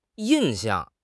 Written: yin4 xiang4

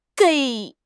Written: gei3

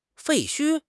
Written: 废墟

